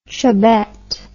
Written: chubbette

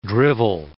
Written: drivel

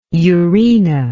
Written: urena

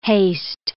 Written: haste

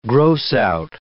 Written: gross-out

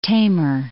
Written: Tamar

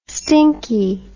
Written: stinkie

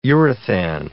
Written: urethan

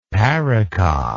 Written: parica

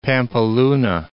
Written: Pampeluna

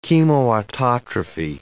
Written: chemoautotrophy

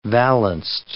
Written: valanced

